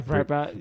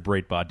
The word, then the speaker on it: Breitbart: How do you pronounce Breitbart? – Yeah, Breitbart. Yeah. – We pronounce it Breitbart down here, mate.